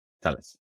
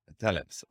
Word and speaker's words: Thales: Okay, Thales. Thales or Thales, uh, depends if, if you pronounce it a French way or the Italian way, you know, there is a little battle between French and Italy.